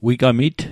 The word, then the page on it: wigamit: Nova Scotia